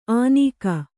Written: ಅನೀಕ

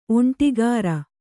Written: ಒಂಟಿಗಾರ

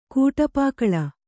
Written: ಕಡುಪಗಲ್

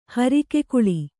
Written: ಹರಕೆಗೊಳ್

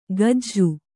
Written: ಕಜ್ಜು

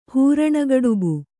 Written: ಹೂರಣಗಡುಬು

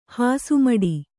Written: ಹಾಸುಮಡಿ

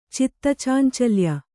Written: ಚಿತ್ತಚಾಂಚಲ್ಯ

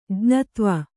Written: ಜ್ಞತ್ವ

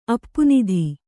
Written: ಅಪ್ಪುನಿಧಿ

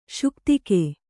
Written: ಶುಕ್ತಿಕೆ

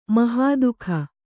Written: ಮಹಾದುಃಖ

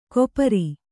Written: ಕೊಪರಿ

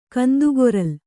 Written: ಕಂದುಗೊರಲ್

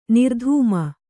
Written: ನಿರ್ಧೂಮ

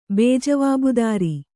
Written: ಬೇಜವಾಬ್ದಾರಿ

